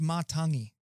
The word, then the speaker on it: Matangi: I've been putting the Australian twang on that, Matangi. So it's Martangi, is it?